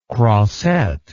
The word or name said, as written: crossette